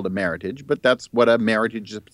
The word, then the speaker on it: Meritage: But now you say it's from California, but the Meritage, pronounced like heritage, was selected from more than 6,000 entries in an international contest.